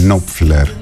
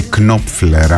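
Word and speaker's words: Knopfler: Στενός φίλος με τον Μαρκ Νόπφλερ ή Κνόπφλερ, ανάλογα με την περιοχή τον προφέρουν διαφορετικά.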